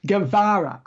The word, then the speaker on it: Guevara: Guevara.